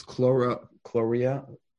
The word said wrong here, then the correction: cholera: C-H-L-O-R-E-A. – Cholera.